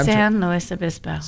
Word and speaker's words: San Luis Obispo: Say the name of the city again. You said it right. – San Luis Obispo.